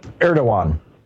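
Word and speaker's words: Erdoğan: Erdogan is how it's pronounced. – Erdogan, yeah. – The G is like a W.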